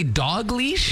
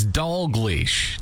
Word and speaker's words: Dalglish: Did you say dog leash? – It's Dalglish. There's an L in there. – D-A-G-L.